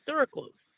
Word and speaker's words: sucralose: It talks about the key ingredient is called sucralose.